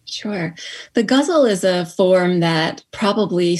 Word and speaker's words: ghazal: And one is a ghazal.